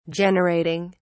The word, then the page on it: generating: British English